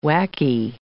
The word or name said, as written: whacky